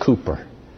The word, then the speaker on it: Cowper: It's spelled Cowper, but it really is pronounced Cooper.